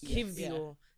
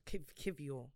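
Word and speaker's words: Kiwior: So is it a V? – I think the Polish pronunciation is Kivior. Yeah. That's easier because I can't say my R's and my W's properly. So Kivior.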